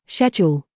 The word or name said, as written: GOOSE